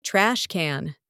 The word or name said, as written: trash can